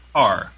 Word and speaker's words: Kerr: So, in terms of the actors, like, not connecting with each other, I'm wondering if anybody feels that about Deborah Kerr? Or Kerr? I never know how to say her name. – I think it's Carr.